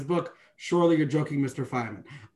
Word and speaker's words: Feynman: I always forget how he's pronounced. Feynman, I think. Feynman, yeah. Okay.